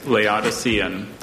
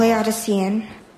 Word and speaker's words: Laodicean: Laodicean. Is that the only pronunciation? – Yes, Laodicean. – Laodicean. – She's worked so hard for this for so long. – Laodicean. L-A-O-D-I-C-E-A-N.